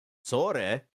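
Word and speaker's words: Sore (それ): Sore, pronounced so-re.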